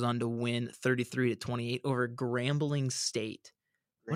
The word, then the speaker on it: Grambling: If I'm pronouncing that right, for all I know, it could be pronounced something different. Like Grom. – It's definitely Grambling.